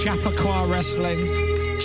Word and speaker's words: Chappaqua Wrestling: That's Chappaqua Wrestling. Chappaqua is the best way to pronounce it properly.